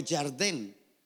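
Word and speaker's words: Yardén (ירדן): Se pronuncia en hebreo Yardén.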